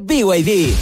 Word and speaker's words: BYD: Se escribe BID, pero se dice BYD.